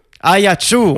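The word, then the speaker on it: Ajaccio: Ayaccio, on prononce.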